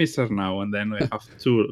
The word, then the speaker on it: Arnau: Like, you've got to roll the R for Arnau.